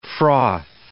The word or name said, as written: froth